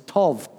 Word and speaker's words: tov: In fact, the Hebrew word for good in Genesis chapter 1 is tov, T-O-V in English, or bov. It's a soft T, but it's hard to pronounce.